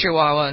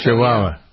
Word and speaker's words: Chihuahua: Is it Chihuahua? Is that how you pronounce the place in the state in Mexico? – There is a Chihuahua in Mexico. – Chihuahua.